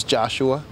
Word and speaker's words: Joshua: My name is Joshua. – How do you spell it? – J-O-S-H-U-A.